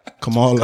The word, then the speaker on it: Kamala: So how do you say it? – Kamala. – Kamala. – That's a fact? – Yeah.